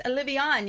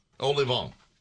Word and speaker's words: Olivon: And then later in the show, Chef Patrice Olivon, you know him. He's from L'Académie de Cuisine. Did I say it wrong? – Yeah. Olivon.